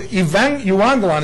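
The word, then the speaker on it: evangelion (εὐαγγέλιον): It's the Greek word evangelion, is actually how you say it.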